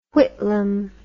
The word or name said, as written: Whitlam